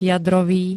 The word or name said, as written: jadrový